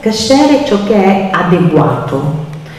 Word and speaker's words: kasher: Voi potete sentire la parola kasher, che è ebraico, oppure kosher, che è la versione Yiddish, americana, perché nella pronuncia ashkenazita talvolta la A diventa O e si ritrae l'accento.